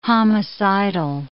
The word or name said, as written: homicidal